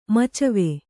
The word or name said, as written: ಮಚ್ವೆ